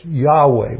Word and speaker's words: Yahweh: but it's Yahweh was the original way that it was pronounced,